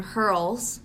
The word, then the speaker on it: Hurls: Follow us on Instagram and Twitter, Manic Pussy Dream Hurls. It's girls with but it's hurls.